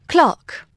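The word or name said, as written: clock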